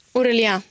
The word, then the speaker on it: Aurélien: Aurélien. – Var du tveksam till mitt uttal? Språk, fascist? – Ja, men lite så. Ja. Mm. Men nu är jag inte det.